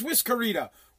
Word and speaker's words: Whiskerita: Whisk Rita. How do you say it? Whisk Rita. Am I saying that right? Whiskey? I feel like it should be Whiskey Rita. It should be Whiskerita. There is a new drink. Yeah, it is Whiskerita.